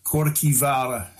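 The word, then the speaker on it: Kirkjuvágr: It's actually pronounced Korkivara in Old Norse.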